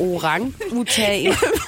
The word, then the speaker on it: orangutan: Det er fordi, det skal udtales sådan her. Orangutan.